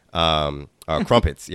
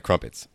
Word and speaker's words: crumpets: And one of the things that we went to do is we went to this there's a shop there that sells crimpets. Crumpets. Yeah, crumpets. So it was. – Yeah, you pronounce things weird. It's Adele, I say Adele, you say Adele, you say crimpets, I say crumpets.